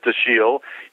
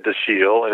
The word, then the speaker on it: Dashiell: So I always pronounce it Dashiell, but there's controversy about that. – Well, it's not controversy so much as like the family says Dashiell. He said Dashiell. It's originally a French name, the Dashiells who came over in the 18th century on his mother's side.